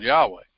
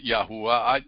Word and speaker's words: Yahweh: It's called Yahweh. And you can pronounce it Yahuwah. It's like Daniel, Daniel. Okay, I understand. You can pronounce it different ways. I personally prefer Yahweh.